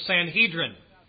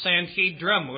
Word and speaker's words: Sanhedrin: They were, without doubt, the body that we know today as the Sanhedrin, or sometimes pronounced Sanhedrum with an M on the end, a word that is not used in the Bible.